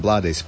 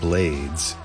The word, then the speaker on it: Blades: That first track we heard during the set was Derek Trucks, the Derek Trucks Band featuring, and I said Ruben Blades, but I think he actually pronounces it Blades. It's pronounced Blades in Spanish-speaking countries, but I believe that the artist himself, Ruben, pronounces it Blades because his dad was from the West Indies.